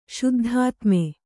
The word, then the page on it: ಶುದ್ಧಾತ್ಮೆ: śuddhātme